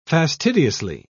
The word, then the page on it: fastidiously: British English